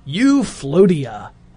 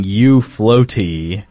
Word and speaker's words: Euphlotea: So it's spelled E-U-P-H-L-O-T-E-A. – And it's pronounced Euphlotia. – Oh, I was thinking Euphloaty. – Nope. – That's what I got, too. – Euphlotia. It is actually pronounced Euphlotia. – As in, like, utopia.